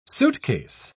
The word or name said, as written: suitcase